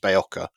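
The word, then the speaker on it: Berocca: So, you know, look, I think it's spelt Barocca, but it's actually pronounced Bayoka if you're actually pronouncing it correctly, because it's an old English name.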